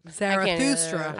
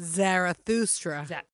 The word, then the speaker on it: Zarathustra: Zarathustra. – How do you do it? What is it? – Zarathustra.